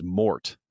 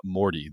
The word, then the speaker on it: Mort: his name is Mort, not Morty, the E is silent.